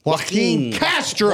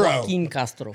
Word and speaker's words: Joaquin Castro: Joaquin Castro. – Joaquin Castro.